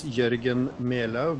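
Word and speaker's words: Jørgen Melau: And please help me with the pronunciation of your name, if you would mind. – Well, thank you for having me. My name is Jørgen Melo, but I think your pronunciation is good enough.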